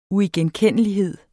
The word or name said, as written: uigenkendelighed